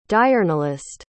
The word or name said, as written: diurnalist